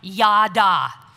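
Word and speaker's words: yada (ידה): But before we get to that, I want to point out that the Hebrew word used here for praise is pronounced yada. Say it with me, yada.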